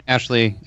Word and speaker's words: Ashley: They've got the Lego dress, the Lego Ahsoka dress that Ashleen Eckstein – did I pronounce her name right? – Ashley Eckstein, yeah.